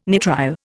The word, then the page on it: nitrile: Indian